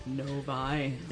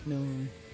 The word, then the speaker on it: Novi: Novi. – And going there for years. – I've always wondered how you pronounce that. – Novi. – Novi. – Novi. N-O-V-I.